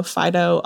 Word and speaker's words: Fidough: For anybody who doesn't know Fidough, um, it's spelt like the bread, so like dough, mm-hmm.